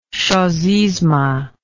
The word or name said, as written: chosisme